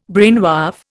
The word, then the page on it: brainwave: Indian